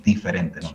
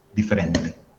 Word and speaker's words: diferente: Y eso ocurre también hay también otros cambios, por ejemplo, la D con la E, la D con la I, los brasileños pronuncian G, ¿vale? Café G, Maya, de otros ejemplos, diferente. Diferente. Los portugueses dirían como diferente.